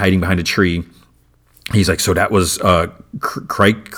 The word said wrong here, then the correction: Crake: How do you say his name? Crake.